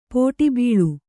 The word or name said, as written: ಪುಟಬಾಳೆ